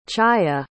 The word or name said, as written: chaya